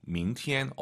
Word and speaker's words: 明天: 낮게 출발하죠. 明天 이게 아니에요. 이성은 아, 明, 明天. 어제는요? – 昨天.